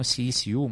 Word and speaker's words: SECU: Eles falam CECU, mas se escreve SECU, e aí o pessoal pergunta o que eu fui buscar no SECU de Las Vegas, mas é CECU, tá?